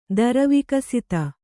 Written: ದರವಿಕಸಿತ

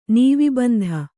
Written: ನೀವೀಬಂಧ

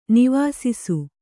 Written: ನಿವಾಸಿಸು

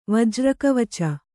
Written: ವಜ್ರಕವಚ

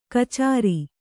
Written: ಖಚರ

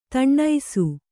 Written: ದಿನಸಿ

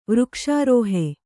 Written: ವೃಕ್ಷರುಹೆ

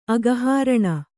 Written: ಅಘಹರಣ

